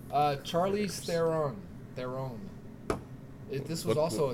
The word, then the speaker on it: Charlize: Charlize Theron.